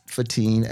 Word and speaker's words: Fatiyn: On Facebook, B-R-O, no dot, Fatim, F-A-T-I-Y-N, on Instagram at Fatim, again, spelled like my name, F-A-T-I-Y-N.